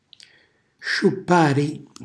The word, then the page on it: sciuppari: Calabrese